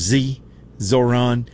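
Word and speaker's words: Zohran: I always pronounce that guy's name wrong. They call him Zoran.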